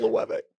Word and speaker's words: Loewe: It's pronounced Loewe.